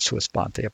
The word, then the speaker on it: sua sponte: My initial concern was that the judge had actually done this sua sponte.